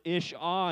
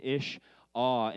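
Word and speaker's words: ish-ah (אִשָּׁה): Man is this word ish, and woman is this word ish-ah. And so even in the way that Hebrew pronounces man and woman, it is this idea that woman has been taken out of man, ish and ish-ah.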